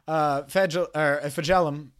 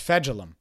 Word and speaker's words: Fejedelem: And Clayton Fejedelem, I always say it wrong. Fejedelem. It's Fejedelem.